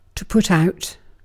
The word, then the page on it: put out: /pʊt aʊt/